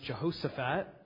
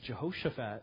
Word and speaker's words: Jehoshaphat: Now, I grew up hearing it pronounced Jehoshaphat, and looking at some reference work and preparing for the sermon, I discovered that phonetically it's Jehoshaphat.